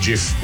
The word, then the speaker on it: gif: And yes, it's pronounced gif. I will die on that hill. And so will the guy who invented them, who insists that it is pronounced gif.